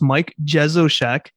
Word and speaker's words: Mike Jesowshek: So I will say it's Mike Jezoshek.